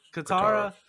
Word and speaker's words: Katara: Katana, or is it? Did I say it right? Katana. – Katara.